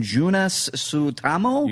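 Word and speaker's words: Joonas Suotamo: Junas Sutamo? – You got it.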